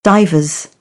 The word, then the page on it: divers: British English; ə